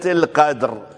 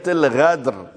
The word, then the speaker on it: القدر: هذا الذي يقلب القاف إلى غين أراد أن يقرأ إنا أنزلناه في ليلة القدر، فكيف يتلفظها؟ إنا أنزلناه في ليلة الغدر، وهذا يقلب المعنى من قدر إلى غدر.